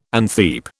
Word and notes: antheap: British English

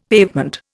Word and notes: pavement: Indian

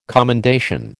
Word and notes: commendation: American English

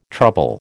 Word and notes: trouble: American English

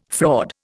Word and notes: fraud: Indian

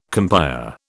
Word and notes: compère: British English

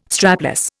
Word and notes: strapless: Indian

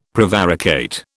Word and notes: prevaricate: British English

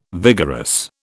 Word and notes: vigorous: British English

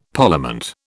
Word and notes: Parliament: British English

